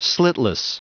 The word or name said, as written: slitless